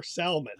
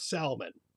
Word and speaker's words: Salmon: No, salmonella came from a guy called Daniel Elmer Salmon, who was a bacteriologist or a surgeon who discovered salmonella. And he pronounced the L in his name.